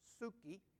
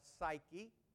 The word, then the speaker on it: psuche: The word soul in Greek is the word psuche, or you might call it psyche. It can be done either way, psuche or psyche. It's spelt psuche in Greek, but most of the time we pronounce that U as a Y oftentimes.